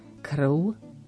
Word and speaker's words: krv: Opäť, tak ako sme mali cirkev, krv, prv, opäť na konci slova V sa číta ako U. Problematické slovo je všetci a tak často ho máme v lekciách používané.